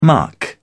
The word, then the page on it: muck: British English